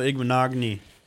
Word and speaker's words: Igbinoghene: I know you love Noah Igbinoghene. I don't know if I said that right. – You nailed it, man.